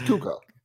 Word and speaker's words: Cuoco: How do you say your name? – Cuoco, I think. – Oh. Cuoco. – No, It's Cuoco.